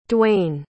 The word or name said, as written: duane